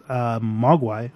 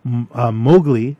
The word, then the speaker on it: Mowgli: Mowgli is the kid from the Jungle Book. Sorry, I just pronounced it wrong. I just wanted to clarify before I went on.